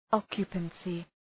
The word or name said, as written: occupancy